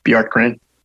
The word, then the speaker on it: Bjorkgren: How do you say his last name? Bjorken? – Bjorkgren. – With the Pacers, you got Mike Budenholzer with the Milwaukee Bucks.